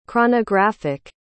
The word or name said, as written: chronographic